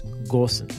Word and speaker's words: Gosens: There's no umlaut. You do say the G. It's Gosens.